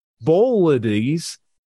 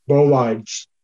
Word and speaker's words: bolides: It was only quite recent that I learned about bolides, which, if traveling at high enough speed, will not... How do you pronounce that? – Bolides. – Bolides. Okay, which, if traveling at... B-O-L-I-D-E-S.